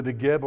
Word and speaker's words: Negev: The Negev, or how it's spelled now when you look at it, is not a B at the end, but it's a V.